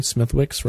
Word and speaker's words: Smithwick's: I'll let you do the First off, the kid is drinking a Smithwick's, which is, I believe I pronounced that correctly, Smithwick's, right? – Yeah, it's nearly gone.